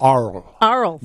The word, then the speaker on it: Arles: Arles, France. Am I pronouncing that right? – Arles. No S. Arles.